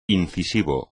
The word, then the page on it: incisivo: espagnol